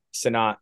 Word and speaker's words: Cenat: Or Cenat, how do you say his last name? – Cenat, yeah.